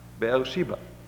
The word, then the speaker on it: Beersheba: and pass not to Beersheba, Beersheba, as you pronounce it in English, I suppose.